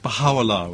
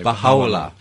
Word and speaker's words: Baha'u'llah: Yes, it's always these pronunciations are always problematic. Baha'u'llah, we say. – Baha'u'llah, okay. – Baha'u'llah.